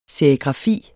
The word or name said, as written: serigrafi